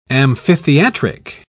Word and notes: amphitheatric: British English

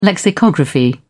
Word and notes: lexicography: British English

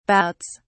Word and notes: bouts: British English

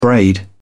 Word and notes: braid: British English

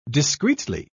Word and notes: discretely: British English

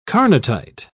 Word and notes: carnotite: British English

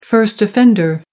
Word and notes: first offender: American English

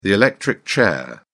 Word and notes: electric chair: British English

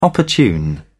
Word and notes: opportune: British English

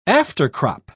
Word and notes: aftercrop: British English

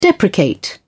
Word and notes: deprecate: British English

